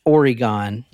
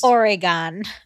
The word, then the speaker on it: Oregon: 2001 Burnside Bridge, Oregon. – You said that's so weird. Okay, go ahead. – I said it how it's pronounced. – Oregon.